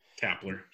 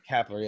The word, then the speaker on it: Kapler: But then you have Gabe Kapler, or Kepler, whatever his name. I can't ever say his name. – Kapler. – Kapler, yeah, it is Kapler.